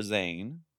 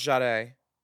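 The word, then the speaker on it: Zhané: Well, girl, when they announced her as the winner, they called her Zane. Because it's spelled Z-H-A-N-E with an accent on the E. – Well, it's pronounced Jade.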